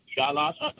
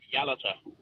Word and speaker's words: Yalata: Just before I try and give an answer, you pronounce the town on the Nullarbor as Yalata. Yalata. Actually pronounced Yalata.